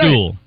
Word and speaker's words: duel: Duel. – Huh? – A duel. – A duel. – Duel. – So you're saying duel to rhyme with tool. – That's correct.